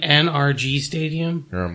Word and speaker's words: NRG Stadium: Now we head to Energy Stadium where the now JJ Wattless Texans take on the Titans as 6-point favorites. – You mean NRG Stadium? – That's how you say it.